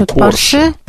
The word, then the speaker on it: Porsche: Porsche, ударение Porsche. – Porsche. – Не надо изображать француженку.